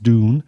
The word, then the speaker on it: dún: Now, the Irish word for fort, or the most usually word, is dún, that's D-U father N, but pronounced dún, and is contained in the names of plenty of Irish towns and areas, such as Dungannon, Dungarvan, Downpatrick, and so on.